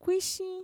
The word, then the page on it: kuixin: Mixtec